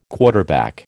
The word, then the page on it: quarterback: American English